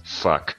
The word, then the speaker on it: Phuc: a three three four minute song called the P H U C, pronounced fuck, the Fuck You Symphony.